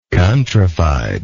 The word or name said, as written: countrifiedness